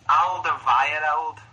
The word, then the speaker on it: Alderweireld: Toby Alderweireld. I'm hoping that's how you say his name. – It's Alderweireld.